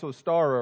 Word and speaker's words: Ostara: The term Easter goes back many centuries, originally connected to a goddess, a pagan goddess of the dawn, pronounced Ostara or Iostre.